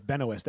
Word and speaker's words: Benoist: It's Benoist, actually. – Melissa Benoist? – Benoist. I'm pretty sure it's Benoit. – I'm pretty sure. – Of all the things you're going to argue with me about pronunciation, is it really? It's Benoist. She's even said it in interviews.